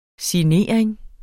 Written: signering

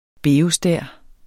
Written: beostær

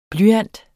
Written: blyant

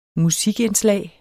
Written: musikindslag

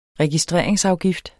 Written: registreringsafgift

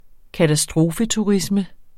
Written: katastrofeturisme

